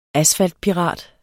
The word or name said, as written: ASFALTPIRAT